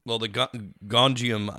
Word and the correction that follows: Gonjiam: It's Gonjiam Haunted Asylum.